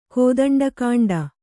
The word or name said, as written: ಕೋದಂಡಕಾಂಡ